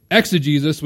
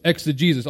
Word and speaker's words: exegesis: Now, this isn't like ex a Jesus person, like Jesus, right? It's exegesis, which is one word, and it's spelled with the G-E-S-I-S, okay? So it's exegesis.